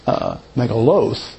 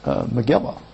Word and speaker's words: Megillah: So, like I said, as I was studying that last night, and I came across it, and I was pronouncing it megaloth because it's plural. But when I started to do a little more research, I realized that modern day Yiddish is Megillah.